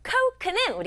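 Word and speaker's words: coke: 코크는 우리 친구들이 알고 있는 콜라예요. 콜라라고 영어로는 하지 않고 코크라고 오 소리 나게 발음해요. 크. 오. 이 소리 나지 않네요. 코크. 콜라.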